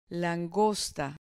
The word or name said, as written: langosta